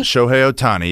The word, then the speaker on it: Shohei Ohtani: Shohei Ohtani? – Shohei Ohtani, yep, got it.